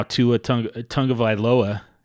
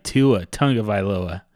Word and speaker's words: Tua Tagovailoa: You know, Justin Herbert, Joe Burrow, and now Tua Tagovailoa. Hey, I got that name. Hey, nice. That's a win right there. I should just stop this podcast right now because I got the name right. Tua Tagovailoa.